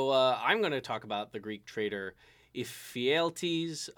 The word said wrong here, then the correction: Ephialtes: of Trachis. I said that right. Ephialtes. Ephialtes. Ephialtes. – Wait, is that what that little e means?